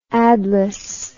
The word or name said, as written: adless